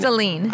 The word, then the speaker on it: Saline: So you mentioned that the communities you represent, some of them, Chelsea, Dexter, Saline, are all Saline or Saline? – Saline. – I always say it wrong.